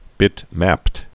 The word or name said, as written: bitmapped